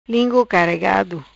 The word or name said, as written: Lingu Caregado